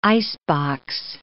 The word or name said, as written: icebox